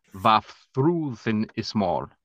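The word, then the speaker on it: Vafþrúðnismál: And the poem is pronounced Vafthrúðinn Ismál.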